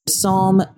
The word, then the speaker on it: Psalm: So the P is silent and the L is silent. – Psalm, the L is not really that silent. It's kind of like the same thing in calm.